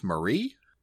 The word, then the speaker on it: Maeri: Although the subtitles on the live action was like M-A-E-R-I. – Yeah, so, yeah. – Which would technically be pronounced Marie.